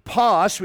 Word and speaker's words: pas: It's the Greek word pas. We pronounce it P-A-S. We phonetically be spelled P-A-S.